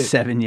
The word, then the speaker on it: Sevigny: Sevigny.